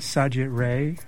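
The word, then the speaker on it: Satyajit Ray: I know I'm going to mispronounce it. Is Sajit Ray? – Oh, it is.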